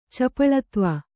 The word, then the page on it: ಚಪಲತ್ವ: Kannada